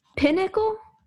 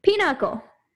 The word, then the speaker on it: Pinochle: How do you even pronounce it? Pinnacle? – Pinochle.